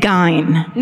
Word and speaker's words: Gein: it's actually pronounced Ed Gein. – No. – That's the correct pronunciation.